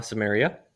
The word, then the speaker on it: Samaria: And Jesus is in, is Samaria is how you say it, right? – Uh-huh, Samaria.